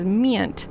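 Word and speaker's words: Miynt: You just heard music by Mint, but that's M-I-Y-N-T, so I'm going to pronounce it as Mint.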